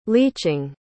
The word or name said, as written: leaching